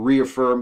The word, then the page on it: reaffirm: American English